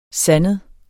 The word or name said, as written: sandet